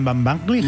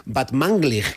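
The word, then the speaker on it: Batmanglij: ¿Este es Rostam Van Banglig? – Muy bien, muy bien. Casi bien pronunciado, ¿eh? O sea, tercero de Radio 3 tiene casi. – Van Banglig. – Bad Manglig, creo que es.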